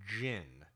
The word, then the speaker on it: Djinn: And Jin, I believe it, the correct pronunciation of her name is Jin. D-J-I-N-N would be Jin. – Yeah, yes. I'm thinking it's, you know, just like it sounds. Like a mythical djinn.